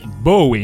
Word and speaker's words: Bowie: Grande vittoria anche per David Bowie, che Bowie, iniziamo a pronunciarlo bene,